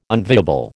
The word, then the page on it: unviable: American English